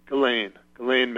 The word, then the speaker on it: Ghislaine: Ghislaine, and it's pronounced, the independent media pronounces it Ghislaine Maxwell. That's what they all pronounce it. – Okay. – I think even the mainstream media pronounces it that way. That must be the way she pronounces it.